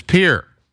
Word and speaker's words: Pierre: Oh, he's also telling me that I'm mispronouncing the name. It is Pierre.